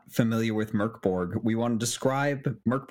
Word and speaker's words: Mörk Borg: So M, diacritic, R-K, B-O-R-G. And it's not pronounced Mörk Borg. It's actually pronounced Mörk Borg, as I learned, 20 minutes ago.